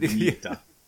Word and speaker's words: vignette: V-I-G or just... – V-I-N-J-E-T-A. – Where did... – Vignetta. – Yeah, where's the J in that? Vinjet? – Vignetta. Yeah. – Vignetta. Wait, I still don't hear a hard J in that. Also, I will defend... English, mm-hmm. In that vignette is a French word. So I mean, I can understand the frustration of silent letters, but you got to take it up with the French, not I.